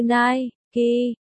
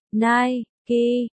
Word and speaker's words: Nike: Nike, Inc, phát âm như Nike, Nike, là nhà cung cấp quần áo và dụng cụ thể thao thương mại công cộng lớn có trụ sở tại Hoa Kỳ.